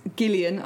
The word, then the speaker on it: Gillian: Gillian, I hear it's a hard G.